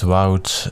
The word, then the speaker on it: Wout: My name is Wout de Donker, that pronounces itself as Woot in French or English.